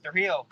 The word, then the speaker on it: Trujillo: Yeah, Trujillo.